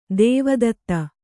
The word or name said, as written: ದೇವದತ್ತ